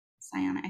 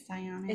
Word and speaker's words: psionic: Psionic, I think that's how you say it. Psionic. I think so.